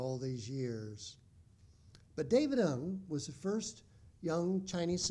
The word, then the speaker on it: Ng: His surname is a diphthong, just N-G in Chinese, and you pronounce that Ng, David Ng.